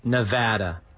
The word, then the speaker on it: Nevada: Mark, for the love of God, it's pronounced Nevada. Everyone in Nevada calls it Nevada. – Oh, did I say Nevada accidentally? – Nevada. – Yeah, Nevada. The people from Nevada hate when you call it Nevada.